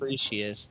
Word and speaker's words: Critias: Timus and Critias. That's how you say it.